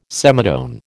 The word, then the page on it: semitone: American English